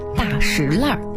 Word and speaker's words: 大栅栏: 比如说前门外的大石烂是极为著名的闹市，俗称大石烂。那为什么要叫大石烂呢，而不叫大栅栏？其实啊，经过考究，它的读音呢属于地名的特殊读音，历史悠久的大石烂读法呢属于保留古音的读法。